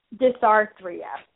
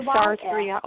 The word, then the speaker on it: Dysarthria: Dysarthria. It's spelled D-Y-S. – Dysarthria, okay. – Yeah. – Is that what's common with, is that a disorder that's common with, like, Down syndrome? – Yes. – Dysarthria, okay.